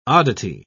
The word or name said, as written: oddity